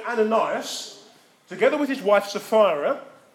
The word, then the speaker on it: Ananias: Ananias.